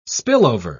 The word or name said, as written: spillover